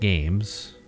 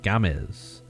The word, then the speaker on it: Games: So the big, giant news for us is that Alex Gomez, it's not games, even though it's spelled, you know, for you gringos, it's spelled G A M E S. That's like games, you know.